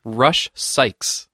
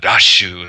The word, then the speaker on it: Rush Sykes: Rush Sykes. – Rush Sykes. – At least okay. – in America. – Yeah, Rashu, if you want to get the katakana pronunciation.